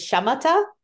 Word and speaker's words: Shamatha: So this, it's called, it's the way to pronounce it is Shamatha.